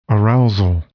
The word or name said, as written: arousal